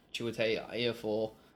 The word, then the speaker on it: Chiwetel Ejiofor: But I believe he used to be exactly what Chiwetel Ejiofor is in the film, an operative. – Well done on pronouncing his name. – Yeah, it's it took some practice.